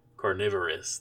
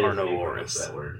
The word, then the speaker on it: carnivorous: Carnivorous. That's how you pronounce that word. Not that carnivorous.